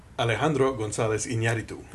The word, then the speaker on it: Alejandro González Iñárritu: Alejandro González Iñárritu.